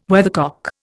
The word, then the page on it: weathercock: Indian